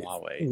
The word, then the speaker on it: Huawei: yes, them uh, what Ho- Hawaii? I don't know the pronunciation. – Huawei, I believe. Huawei.